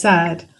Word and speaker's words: Saad: Did I pronounce that correctly? – Yes, Saad.